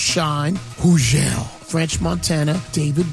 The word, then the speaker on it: Hugel: I told you, Hugel, the silent G, Hugel, is going to touch down right here on Hot 97, November 25th, Thanksgiving Eve, 5 p.m., Funk Flex, Bobby Trends, and Hugel.